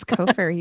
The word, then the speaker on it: Cofer: I always want to say Cofer, but it's Cofer. – It's Cofer.